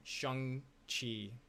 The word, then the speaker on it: Shang-Chi: Shang-Chi, or Shang-Chi. That's proper pronunciation.